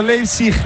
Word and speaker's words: Leipzig: Que ese tifo simulando un aeropuerto destino Leipzig, ahora sí lo he dicho bien, está muy chulo.